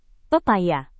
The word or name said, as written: pepaya